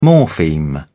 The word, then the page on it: morpheme: British English